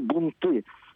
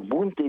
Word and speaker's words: бунты: Все-таки по поводу ударения во множественном числе бунт, да? – Оба варианта возможны. – Оба возможны. Mm-hmm. Я бы сказал, что я бы предпочел не переносить ударение на окончание «голодные бунты». Но вот словарь, как свидетельствует Валерий Анатольевич, разрешает и «бунты».